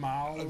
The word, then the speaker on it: Malle: Malle? – Yeah, Louis Malle.